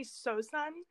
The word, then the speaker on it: Seosan: Yeah, so it's actually Sosan. In English, it's spelled S-E-O-S-A-N.